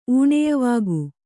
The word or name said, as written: ಊಣಯವಿಕ್ಕು